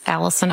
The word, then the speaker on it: Allison: It's Allison Oizuka, A-L-L-I-S-O-N. Last name is O-Z-G-A.